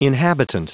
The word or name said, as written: inhabitant